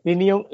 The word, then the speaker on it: Tyrion: perdón, se dice Tinion Lannister.